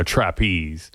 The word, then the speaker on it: Trapeze: Yeah, I just hadn't gotten enough of Glenn Hughes today with the Deep Purple thing going into trapeze. I actually always call it a trapeze, but I guess the British call it trapeze. You know, they put the accent on the second syllable.